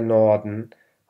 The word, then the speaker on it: Norden: كلمة der Norden هون حرف الآ يلفظ لفظ خفيف، وباقي الكلمات تلفظ كما هي مكتوبة، ننطق كل الأحرف.